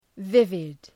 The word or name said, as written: vivid